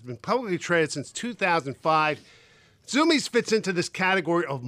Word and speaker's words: Zumiez: Based on the pronunciation, you'd think it's spelled Z-O-O-M-I-E-S. Not even close. Zoomies is spelled Z-U-M-I-E-Z.